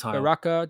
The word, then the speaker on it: Baraka: Yeah, Baraka, that's correct.